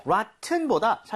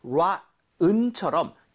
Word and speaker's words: rotten: rotten보다 사실은 rotten처럼 T 사운드가 굉장히 약화되거든요.